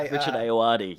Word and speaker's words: Richard Ayoade: The guy Richard Ayoade.